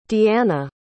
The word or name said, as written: deeanna